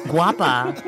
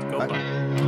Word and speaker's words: Guapa: Guapa. – Guapa. – Guapa means beautiful in Spanish. – The U and A are the other way around. Gopa.